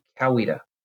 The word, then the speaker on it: Coweta: Do you know how to pronounce our county name? We hear a lot of pronunciations such as Coweta, Coweta, but it's actually Coweta.